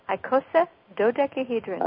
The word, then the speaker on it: icosadodecahedron: Right, so when I envisioned your icosadodecahedron, did I say that right? – Icosadodecahedron.